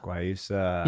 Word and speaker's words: Guayusa: Is it Guyana? – Guayusa? – Guayusa. – Yeah, I think that's how you pronounce it.